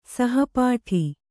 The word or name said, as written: ಸಹಪಾಠಿ